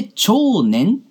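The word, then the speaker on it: 奝然: 長年というのは少し難しい字でございまして、長というのは大きいという字の下にですね、なんか、一周して田中みな実好きだわ、の、周という字を書いて、長と読むんですね。そして、宇垣美里とか、全然好きじゃないんですけど、の禅。 2 回出てきましたね。大自然の禅ですね。はい。これで長年と読みます。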